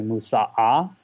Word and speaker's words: Moussaka: But I would say Moussaka is always a good go-to, although in Egyptian Arabic, they don't pronounce the kaf, so it's actually Moussa'a.